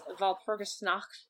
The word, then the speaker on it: Walpurgisnacht: Ended up at Walpurgisnacht.